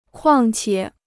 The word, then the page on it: 况且: kuàng qiě